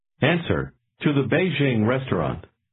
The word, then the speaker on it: Beijing: Beijing is pronounced with a falling, rising tone, followed by a high-level tone. Say it again. – Beijing. Beijing.